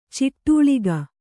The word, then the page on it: ಚಡಾಳಿಕೆ: Kannada